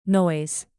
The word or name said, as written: noise